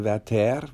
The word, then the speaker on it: Werther: Ich habe mich mit dem Leben von Jules Massenet nie beschäftigt, aber ich war damals sehr glücklich, als der Werther, wie er französisch ausgesprochen wird, endlich auf die Bühnen wieder kam mit einer Inszenierung hier in München.